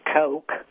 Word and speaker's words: Koch: You know you're dealing with a bunch of arrogant SOBs when they spell their name K-O-C-H and pronounce it Coke.